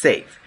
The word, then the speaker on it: SAFe: It's called SAFE, or pronounced as SAFE, S-A-F, small e.